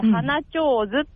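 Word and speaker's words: 花手水: 花、フラワーのお花の、はい、手の水って書いて、花上手って読むんですけど、うん、知ってます？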